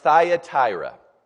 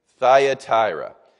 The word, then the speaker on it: Thyatira: And this is the church that's pronounced Thyatira. Thyatira.